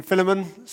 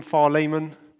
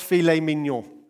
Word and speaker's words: Philemon: Now, yes, some call him Philemon, some call him Philemon. You say tomato, I say tomato. Let's call the whole thing off. I do know it's not pronounced filet mignon. So we can all agree on that one.